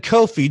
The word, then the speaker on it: Ko-fi: I pronounced it right and now you switch? This is ridiculous. – Did he switch? – He did. – Everybody caught that I said Ko-fi.